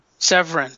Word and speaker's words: Severin: Severin? – Severin, thank you.